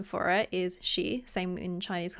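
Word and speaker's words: Shi (四): The same goes for the number 4, which I believe is Shi. – Yes, Shi.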